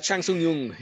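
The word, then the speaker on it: Chan Sung Jung: How do you pronounce it? Chang Sung Jung? – Chang Sung Jung, yes.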